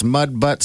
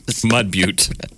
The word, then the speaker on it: Butte: I don't know if they have a mayor, but it's Mud Butt, South Dakota. Shut up. yeah. Mud Butte. I was going to say. I pronounce it butt. B U T T E.